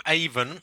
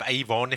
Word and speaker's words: Avon: Well, the full title of Shakespeare, or the Bard of Avon, or the Bard of Avon, if you want the American pronunciation.